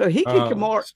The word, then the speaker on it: hikikomori: In Japan, they even have a name for it called hikikomori. And Kara informs me that I almost get the pronunciation right. – Oh, you got it. – So hikikomori.